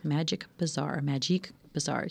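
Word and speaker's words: Magique Bazaar: Magic Bazaar. You can pronounce it either way. We're fine with that.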